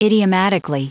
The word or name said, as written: idiomatically